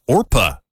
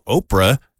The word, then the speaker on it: Orpah: She said that on her birth certificate, her God-given name is actually Orpah, you know, from the Bible story of Ruth. Yeah, relatives, though, over the years kept mispronouncing it, and so Oprah just kind of stuck.